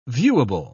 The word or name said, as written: viewable